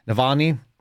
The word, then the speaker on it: Navalny: Navalny.